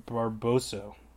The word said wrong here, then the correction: Barroso: Barboso. Barroso? There's no, there's no other B in there. I'm thinking of Barbo, Bar- Barbero, Bar- Bar- Barbosa is who I'm thinking of. I can't even talk. Barroso, Francimar Barroso. Okay, I think that's how you say it, but that's who I'm going with in that that bout.